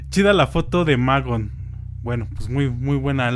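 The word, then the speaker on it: Magón: Pero con acento en la O. – Magon.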